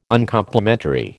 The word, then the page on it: Uncomplimentary: American English